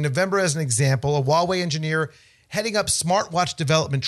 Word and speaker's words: Huawei: Yeah, Huawei.